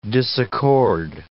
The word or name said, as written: disaccord